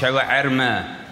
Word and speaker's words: Shawarma: Eigentlich richtig ausgesprochen. Shawarma. Das H ist woanders.